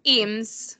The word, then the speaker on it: Eames: But is it Eames or Ames? I'm not really sure. – Eames. – Eames, okay. – So that's how they pronounced it in the movie.